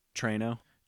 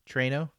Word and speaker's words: Treno: How do you say it? – Treno. – Treno. That's how I said it too. Treno. – Which is funny because there's no I.